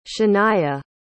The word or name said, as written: shania